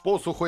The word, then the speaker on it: посуху: Посуху я правильно сказал. Я отметил себе посуху, да. – Ну, естественно. – А то вдруг ударение не туда паза.